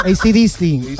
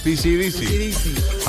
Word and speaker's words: AC/DC: Bueno, no se olviden nunca de la pronunciación de AC/DC. – Digo, AC/DC, ha dicho ella. – Que no es PC/DC.